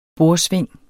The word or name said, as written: borsving